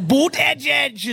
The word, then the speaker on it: Buttigieg: Boot Edge Edge is what they call him.